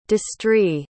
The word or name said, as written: destrey